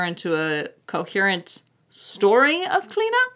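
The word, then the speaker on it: Clíodhna: So it is C-L-I-O-D-H-N-A, mm-hmm. – if you're looking her up. Pronounced Clina.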